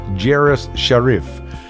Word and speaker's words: Jairus Sharif: His name is spelled J-A-I-R-U-S-S-H-A-R-I-F, Jeref Sharif. Jeref Sharif played all instruments on the recording.